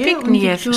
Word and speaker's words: Zbigniew: Viktoria und Zbigniew. – Zbigniew war er. – Zbigniew. Schreibt man Z-B-I-G-N-I-E-W.